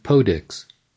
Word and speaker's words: PODXS: And yes, it's pronounced Podix.